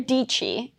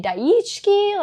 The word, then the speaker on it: Dichi: My name is Sarah Dichi, and it's spelled crazy. Like, they would say Daichki, like, what the hell even is that? And a fellow YouTuber basically titled one of our collaboration videos Rhymes with Peachy. And I was like, oh, interesting. Sarah Dichi rhymes with Peachy.